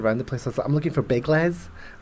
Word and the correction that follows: Biggles: Obviously mispronounced. Oh, he means Biggles.